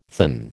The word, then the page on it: thin: American English